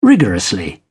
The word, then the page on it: rigorously: British English